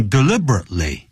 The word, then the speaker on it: deliberately: 那个时候就等于 deliberately. D-E-L-I-B-E-R-A-T-E-L-Y. Deliberately. 我们中国人常常会念 deliberately, 不是 tly. 那个 deliberate, 停顿一下 ,ly. Deliberately.